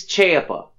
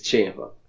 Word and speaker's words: Ciampa: I think it's Ciampa. – But are you sure? – Nah, but that's how they say it on NXT TV, is Ciampa. – I like it. It's Ciampa, so we'll go with that.